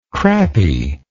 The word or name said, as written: crappie